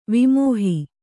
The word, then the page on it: ವಿಮೋಹಿ: vimōhi